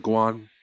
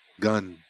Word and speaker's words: Gun: So yeah, I'm of the Guan, actually Gun Kingdom is how it's pronounced, but it looks like Guan, so I'm gonna say Guan.